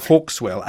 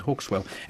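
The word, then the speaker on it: Hauxwell: Hawkswell, I believe. – Hawkswell, H-A-U-X. Yes. As if it were a French name, then with Well on the end, Hawkswell, out today.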